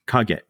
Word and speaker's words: Kage: Wait, so what's the second syllable? Is it a G? – K-A-G-E. Kage. – Oh, okay, got it. So there's no T at the end.